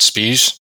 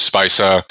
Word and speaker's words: Speise: Uh, no, that's actually correct. Um, and for the first bit of, uh, of my using of the, the term, I um, called it Spies because I didn't, um, didn't really look up how to pronounce it, which is why the original naming of the um, of the presentation is uh, stees with spees. um, so, but it is it is Speise,